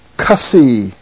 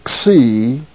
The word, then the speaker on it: Xi: It's pronounced XI. You hit the K and then the S right after it. A lot of people pronounce this the Cussy, but there's no A in this. It's XI. It is the KS sound, as in The X in the word box, it's X.